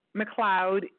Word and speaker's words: McLeod: and so it is like McLeod, M-C-L-E-O-D, like Mary McLeod Bethune, on whose day is this interview, as we live and breathe, it would be her 75th birthday.